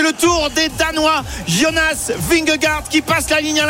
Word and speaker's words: Vingegaard: D'ailleurs, on ne sait pas trop comment ça se prononce. Thibaut Texier disait Vingegaard tout à l'heure. – Oui, bah c'est plutôt la bonne version.